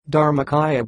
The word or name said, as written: dharmakaya